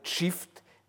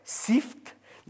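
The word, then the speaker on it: çift: Mais euh, je signale que ce n'est pas ainsi qu'ils sont transcrits couramment dans l'alphabet turc actuel, alphabet turc latin, où ce mot shift s'écrira CCD IFT. Mais si je l'avais écrit ainsi, beaucoup d'entre vous le prononceraient SIFT, n'est-ce pas Ce qui n'est pas la bonne prononciation et il suffit euh,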